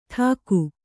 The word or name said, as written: ಡಾಕು